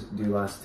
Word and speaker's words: doulos: So the name is Doulos, D-O-U-L-O-S. You want to start us off with this one? – Yeah. So the word doulos actually comes from the Greek word. It's pronounced the same way, doulos.